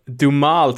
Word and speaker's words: Duhamel: Dumal tror jag man uttalar det.